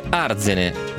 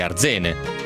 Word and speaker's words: Arzene: E poi invece, questo me lo scrive non so chi, perché non si firma, si dice Arzene, senza accento sulla prima lettera. No, quindi Arzene, senza accento sulla A, giusto? Si dice Arzene, provincia di Pordenone.